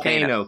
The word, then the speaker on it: Kano: How do you pronounce it? – Kano. – Kano.